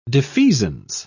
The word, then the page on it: defeasance: British English